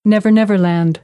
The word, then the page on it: never never land: American English